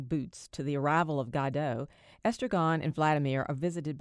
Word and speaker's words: Godot: The play centers on two hobo-type characters, Estragon and Vladimir, who are waiting for someone called Godot. This pronunciation is proper, according to director James Ricks.